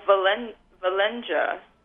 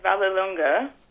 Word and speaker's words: Vallelunga: The one, I believe, and my pronunciation might be way off, the Valenga. – Vallelunga.